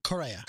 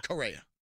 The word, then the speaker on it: Correa: How do you pronounce his last name? – Correa. – Correa.